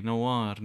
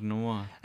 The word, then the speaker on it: نوار: لا بدها تلفظه نافار يا حبيبتي نوار نوار هي بتعرف هلا هي مو موضوعنا لليوم